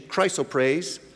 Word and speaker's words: chrysoprase: the tenth chrysoprase, I checked the pronunciation, chrysoprase, the 11th, jacinth, the 12th, amethyst.